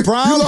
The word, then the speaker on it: Braun: Is it Braun? – It's Braun, so but it's spelled B-R-A-U-N.